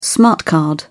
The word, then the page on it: smart card: British English